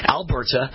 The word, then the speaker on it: Alberta: In Alberta, excuse me, put the stress on the right syllable, right? Alberta.